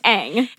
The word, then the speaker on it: Aang: It's pronounced Aang.